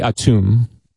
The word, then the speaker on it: Atum: Well, the correct pronunciation, which I'm not from Egypt, I believe is Atum.